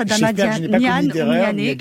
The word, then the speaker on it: Adama Niané: C'est ça, c'est comme ça que ça se prononce, Adama Niané.